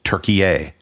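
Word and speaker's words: Türkiye: They want people to start using the traditional pronunciation and spelling of the country, which is Turkiye, pronounced T, U with an umlaut, R-K-I-Y-E.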